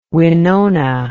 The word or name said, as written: Wenonah